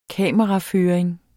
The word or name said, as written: kameraføring